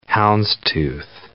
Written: houndstooth

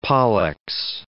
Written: pollex

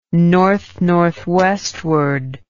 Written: northnorthwestward

